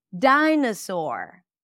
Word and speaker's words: dinosaur: Dinosaur. Dina, na, na. Can you hear it? Spelled with O, pronounced as a. Dinosaur. And finally, all the I-O-N suffixes are pronounced as un, or in this case, shin, shin, shin.